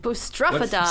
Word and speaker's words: boustrophedon: Boustrophedon.